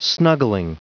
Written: snuggling

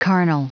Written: carnal

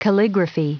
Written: calligraphy

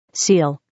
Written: seal

